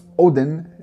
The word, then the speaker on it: Auðn: Estamos hablando sobre la banda Odin, en la pronunciación, es una banda islandesa de black metal atmosférico muy bueno, muy melódico.